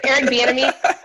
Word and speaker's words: Bieniemy: You brought up Bieniemy. Did I say that right? – Yeah, Eric Bieniemy.